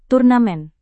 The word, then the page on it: turnamen: indonesian